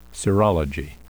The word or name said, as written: serology